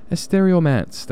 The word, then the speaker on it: Estereomance: Oh, am I pronouncing that right? Estereo Romance. That's what it is. Estereo Romance.